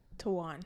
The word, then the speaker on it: Taiwan: And they worked in Vietnam and Taiwan. Did I say that right? Taiwan. Yeah. – Taiwan.